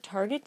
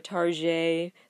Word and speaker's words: Target: It's part of the reason people jokingly will call it Target, the French pronunciation, because people don't feel like they're shopping at a discount store.